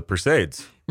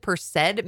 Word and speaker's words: Perseid: That's not how you say it, apparently. It's like Perseus. It comes from the root word Perseus. So it's like Perseid. – Perseids. – Yeah, Perseid meteor shower.